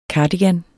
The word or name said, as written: cardigan